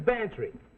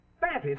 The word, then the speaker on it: Bantreagh: Sounds like Cloud, but it's spelled M-A-C-L-E-O-D. Well, his is Bantry. Go on, spell it. Bantry, it sounds like pantry. – I ain't no gambler, Mac. – Well, get this, for free. It sounds like pantry, but it's spelled B-A-N-T-R-E-A-G-H.